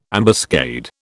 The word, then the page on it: ambuscade: British English